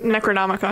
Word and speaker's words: Necronomicon: Necronomicon.